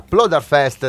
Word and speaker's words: Ploderfest: Luca Crater fa parte degli Old Soccer, il gruppo folk che organizza la Ploderfest di Sappada. Ho detto giusto? Come l'ho letto? – Hai detto benissimo.